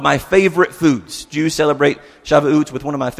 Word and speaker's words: Shavuot: Shavuot uh, is how I believe that it's pronounced, Shavuot.